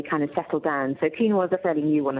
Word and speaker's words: quinoa: It's spelled Q-U-I-N-O-A. Hence quinoa, but it's pronounced quinoa.